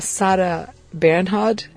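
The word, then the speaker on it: Sarah Bernhardt: Ela perguntou quem era Sarah Bernhard, é isso, assim que pronuncia? – Uhum.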